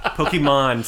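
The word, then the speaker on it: Pokémon: Pokemon, sorry, not man.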